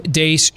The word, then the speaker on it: Deace: Steve Dace, just look me up. Follow me on Twitter at Steve Dace Show. Last name is spelled D-E-A-C-E.